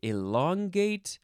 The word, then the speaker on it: elongate: You can say elongate, elongate. You can emphasize that syllable and say elongate. Elongate, elongate, all those different ways.